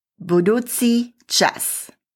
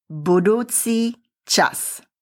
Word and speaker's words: budúci čas: First, how do you say future tense in Slovak? It is budúci čas. Repeat, budúci čas.